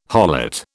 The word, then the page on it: harlot: British English